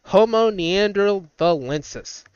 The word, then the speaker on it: Homo neanderthalensis: All right, so number eight in our list, Homo neanderthalensis. Yes, I said that right. Homo neanderthalensis.